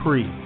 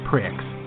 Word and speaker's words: Prix: And by the way, P-R-I-X is pronounced pre, not pricks.